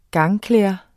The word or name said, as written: gangklæder